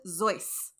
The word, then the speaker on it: Seuss: In Springfield, Massachusetts on March 2nd, 1904, his parents were German immigrants, and the name Seuss was actually pronounced Zeus. – Zeus? – Yep. Theodore Zeus Geisel. But the Americanized version that sounded kind of like Juice stuck around because we can't let people keep their own cultures.